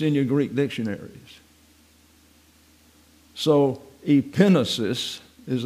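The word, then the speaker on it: epignosis: So people sort of question it's not epinosis.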